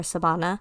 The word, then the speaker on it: Savannah: So, my family uh, actually pronounces my name Savannah or Sabana because um, in the Filipino alphabet, there are no V's, so they say them with a B.